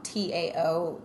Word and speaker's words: Tao: TAO is pronounced Tao.